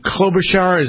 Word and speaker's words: Klobuchar: I noticed Senator Amy Klobuchar, is that how we pronounce her name? – Yes.